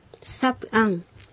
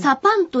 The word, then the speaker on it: サプアン: サプアン。 – サプアン。私たちは川などを降りる。この場合、うん、サパンという発音になることもあります。